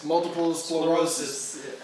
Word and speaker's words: multiple sclerosis: And best friend, 40, has a disease called MS, multiple sclerosis.